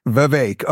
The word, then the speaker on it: Vivek: I'm not like a picky, you know, pronunciation corrector, but yeah, Vivek, like cake. Yeah. – Vivek.